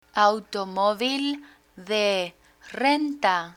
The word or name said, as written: automovil de renta